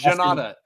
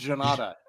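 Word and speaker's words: Jhonata: Oh, Janta. – Janta Denise, Brazil. – Or no, it's Janata. This one's Janata. – Yes, it's Janata. That's right. There's an A there. Extra A. Janata Denise versus Austin Lane.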